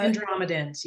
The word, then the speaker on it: Andromedans: Uh, Andromedan, uh, how do you say this? Andromedans? – Uh, Andromedans, yeah.